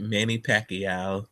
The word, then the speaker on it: Pacquiao: Manny Pacquiao.